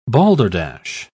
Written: balderdash

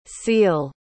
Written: ciel

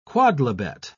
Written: quodlibet

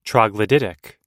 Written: troglodytic

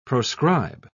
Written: proscribe

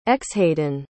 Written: xhaiden

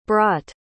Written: brott

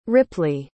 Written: ripley

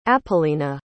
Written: apollina

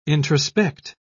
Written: introspect

